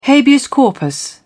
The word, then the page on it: habeas corpus: British English